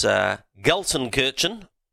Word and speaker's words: Gelsenkirchen: They drive at Wolfvega in the Netherlands, Gelsenkirchen, and I checked the pronunciation of that, at Germany, Mons in Belgium, and they drive at Berlin in Germany.